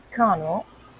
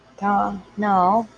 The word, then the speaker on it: Connal: It's spelled C-O-N-N-A-L, so I'm going to call it Connell. Connell.